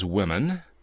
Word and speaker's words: women: I said, can you name a common word in which O appears between two consonants and the O is pronounced like a short I? – Now, give us an example of what a short I sounds like. – Yeah, like pit or big. – Okay, and the answer? – Well, the intended of the answer is women.